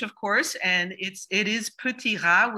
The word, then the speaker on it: Petit Rat: It is called Petit Rat. I tried my best. It is spelled like Petite Rat, but hi. – Thank you, Ashlyn. And that was pretty good.